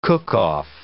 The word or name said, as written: cook-off